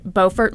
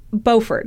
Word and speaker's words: Beaufort: And I said, I assume it's pronounced like Beaufort, South Carolina. My mistake. There's a Beaufort, North Carolina that's pronounced Beaufort, but apparently in South Carolina, they call it Beaufort.